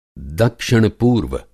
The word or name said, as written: दक्षिण-पूर्व